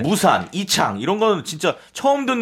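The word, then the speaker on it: 우샨 (巫山): 사실 그것도 우리말 발음이 무산이고 네네. – 실제로 중국 발음은 우샨입니다. 우샨. – 네. 우샨.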